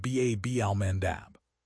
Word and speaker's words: Bab al-Mandeb: We are seeing a global energy market that is hypersensitive to geopolitical choke points in the Strait of Hormuz and the Bab al-Mandeb Strait, pronounced B-A-B al-Mandab.